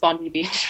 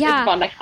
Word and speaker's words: Bondi: Everybody thinks it's Bondi Beach. – Oh, okay. – It's Bondi.